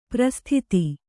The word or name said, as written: ಪರಿಸ್ಥಿತಿ